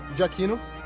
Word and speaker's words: Giacchino: Giacchino. – Ah, bom. Olha, perdão por todas as pronúncias erradas que saíram nesse podcast. – Giacchino, porque fala fluentemente italiano, né? – Exatamente, ele sabe exatamente tudo.